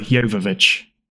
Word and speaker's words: Jovovich: It's absolutely Jovovich. Not. It's Mila Jovovich.